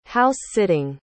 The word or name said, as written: house-sitting